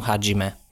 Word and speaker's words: Hajime: Ja, nej, det uttalas nog Hajime.